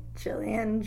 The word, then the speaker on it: Jillian: Jillian, J-I-L-L-I-A-N, Emerson, E-M-E-R-S-O-N.